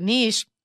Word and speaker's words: niche: I guess says there are two common pronunciation variants, both of which are currently considered correct. So they're both correct. – Okay. – But they gave us the examples like niche rhymes with sheesh.